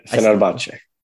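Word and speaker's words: Fenerbahçe: Did I say it right? – Yeah, Fenerbahce. You said it pretty good. Just the E at the end, you pronounced.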